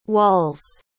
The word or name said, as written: walls